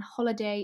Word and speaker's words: Holliday: So my handle is GeorgiaHolliday_, and Holliday is spelt with two L's, because annoyingly, that's how my husband spells his last name.